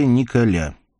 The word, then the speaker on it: Николя: Его звали Николя. Именно так на французский манер, с ударением на последний слог, он и предпочитал представляться людям, с ним ранее незнакомым.